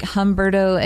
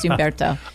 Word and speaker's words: Umberto: It looks like Humberto if you don't speak Spanish, but it's actually pronounced Umberto.